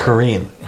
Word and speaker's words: Korine: Is how you say Korine.